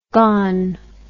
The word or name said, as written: gon